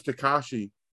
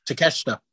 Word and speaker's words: Takeshita: And I know it's Takashi. Takasha? – Takeshita. – Takeshita. Yeah. – It's Takeshita. Yeah, not a bad little match. Game for what it was. I get it, especially putting Takeshita over.